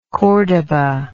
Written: cordoba

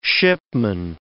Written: shipman

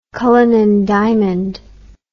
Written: cullinan diamond

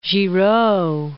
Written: Giraud

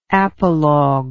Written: apologal

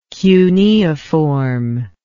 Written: cuneiform

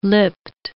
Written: lipped

